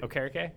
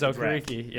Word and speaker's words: Okereke: I am impressed you said his name correctly. – Okereke? – Yes, because I still say Okereke all the time because in my ear, it's Pat McAfee. – It's Okereke. Yep, exactly.